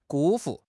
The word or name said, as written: 辜负